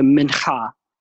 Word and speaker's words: mincha: It the Hebrew word behind it, and forgive me on my Hebrew pronunciation, but the mincha. That's my. You like how I really that guttural H there?